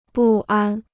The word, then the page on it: 不安: bù ān